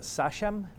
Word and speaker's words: sachem: He was a sachem. Is that how you pronounce it? – Right. – S-A-C-H-E-M.